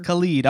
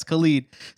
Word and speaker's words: Khalid: And we are here today to talk about the one and only Khalid. That is how you say his name, right? – Yeah, yo, yes, sir. – Khalid. All right, yes, Khalid.